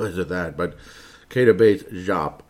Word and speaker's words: Diop: It's Jop. Do you remember Desogna Jop? Yeah, I know. That's a real easy name to pronounce and everything. But the name's been out there before. It's Jop. It's not Diop.